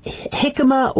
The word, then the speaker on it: jicama: Jicama or jicama, take your pick as to how you want mm-hmm. to pronounce it, comes from Mexico and the Americas, essentially.